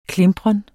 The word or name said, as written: klimpren